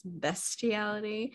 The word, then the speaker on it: bestiality: When they were picking on Lewis for knowing how to say that, even though he still said it wrong, because it's bestiality.